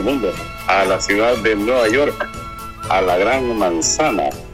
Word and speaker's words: Nueva York: Aquí los periodistas siempre dicen a Nueva York, y en, no sé, Nueva York, pero es Nueva York, tiene que sonar la K para que sea correctamente pronunciado el nombre de la Gran Manzana, la gran ciudad del capital del mundo, y ahora es capital del mundo, digo.